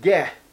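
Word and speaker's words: ge (γῆ): Well, the Greek word that is translated earth here is the word ge, G-E, ge, literally ge, G-E, long E.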